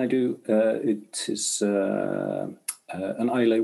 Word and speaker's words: Islay: Islay.